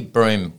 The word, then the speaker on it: Brougham: I think locally, broom works. – All right. Yes. Broom as in sweeping. – As in sweep the broom.